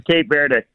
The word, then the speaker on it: Cape Verde: The English pronunciation is Cape Verde.